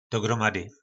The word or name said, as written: dogromady